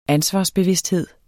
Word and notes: ansvarsbevidsthed: ˈansvɑs-

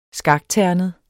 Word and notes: skakternet: -ˌtæɐ̯nəð; -ˌtæɐ̯ˀnəð

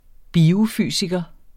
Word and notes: biofysiker: [ ˈbiːo- ]

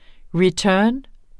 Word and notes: return: ɹiˈtœːn; ʁiˈtœːn

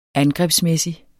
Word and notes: angrebsmæssig: [ ˈangʁεbsˌmεˀsi ]; [ -ˌmεsi ]

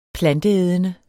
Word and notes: planteædende: [ -ˌεːðənə ]; [ -ˌεˀðənə ]

